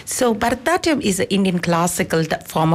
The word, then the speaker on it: Bharatanatyam: So, for listeners who may not be familiar with Bataranium, which I don't think I'm actually saying that right